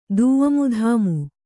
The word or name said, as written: ದೂಮುಧಾಮು